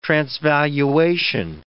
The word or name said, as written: transvaluation